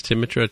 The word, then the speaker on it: Timmitra: And I always say my name is Timitra, TIMMITRA, and that's how to get a hold of me on the Twitter machine.